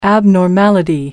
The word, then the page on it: abnormality: American English